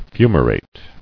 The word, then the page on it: Fumarate: fu·ma·rate